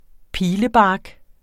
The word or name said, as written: pilebark